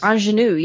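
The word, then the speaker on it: Aunjanue: Anjanou, yeah.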